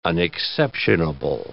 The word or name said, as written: unexceptionable